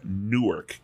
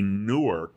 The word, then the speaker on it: Newark: Que você com, começa a pronunciar New York e Newark. É parecido, né? Aí você tem um problema com o brasileiro, porque dá, é difícil é. distinguir. É. Até para nós é difícil distinguir. Newark.